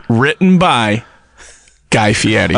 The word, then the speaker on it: Fieri: And I will, you know, it's pronounced Fieri, but his real name is Fairy.